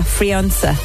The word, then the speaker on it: Phrionsa: Is it, how do you say prince in Irish? Frinch. – Is it like Prunchus? – P-H-R-I-O-N-S-A. Frionsa.